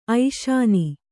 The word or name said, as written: ಐಶಾನಿ